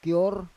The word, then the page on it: kior: Roon